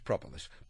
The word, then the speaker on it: propolis: For the last 3 weeks they put different pronunciations up on top of this, and today they put no pronunciations on top of it. So it's propolis.